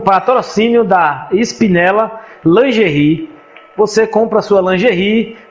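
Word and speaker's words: Spinella: Espinela. S mudo P-I-N-E-L-L-A. Espinela Oficial. Lingerie de luxo.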